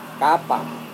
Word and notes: kapa: Serewen

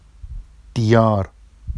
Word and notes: dijɑr: Vafsi

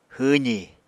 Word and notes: hwwzi: apatani

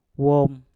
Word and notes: wom: mpur

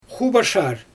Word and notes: xubaʃar: Xyzyl

